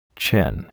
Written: chin

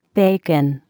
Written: bacon

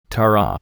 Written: taro

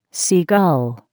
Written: sea gull

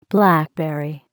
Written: blackberry

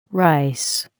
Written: rice

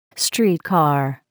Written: streetcar

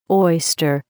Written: oyster

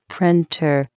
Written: printer

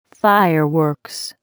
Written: fireworks

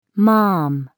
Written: mom

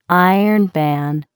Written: iron pan

